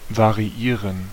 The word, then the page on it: variieren: /vaʀiˈiːʀən/